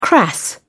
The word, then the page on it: crass: British English